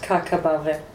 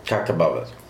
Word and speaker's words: Kakabave: Kabave, är det som man uttalar namnet? – Amine, ja. – Amine, och efternamnet? – Kakabave. – Kakabave. Precis.